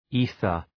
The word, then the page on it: ether: ‘i:ɵər